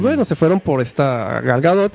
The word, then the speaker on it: Gal Gadot: Gal Gadot. Así tal como suena. Gal Gadot. Gadot. G-A-D-O-T.